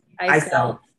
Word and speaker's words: Eiselt: Eiselt.